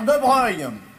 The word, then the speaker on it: de Broglie: Affaire de Breuil. Ça s'écrit de Broglie. C'est des noms de faucheton, ça s'écrit pas comme ça se prononce.